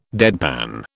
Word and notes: deadpan: British English

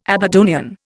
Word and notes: Aberdonian: Indian

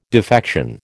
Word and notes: defection: American English

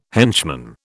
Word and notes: henchman: British English

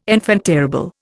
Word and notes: enfant terrible: Indian

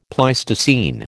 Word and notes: Pleistocene: American English

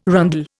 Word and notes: rondel: Indian

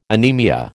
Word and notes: anemia: American English

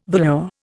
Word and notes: velour: Indian

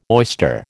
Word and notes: oyster: American English

